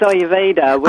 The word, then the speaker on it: Ayurveda: And am I pronouncing Ayurveda right? – It's Ayurveda.